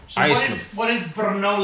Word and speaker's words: Brno: It's B-R-N-O.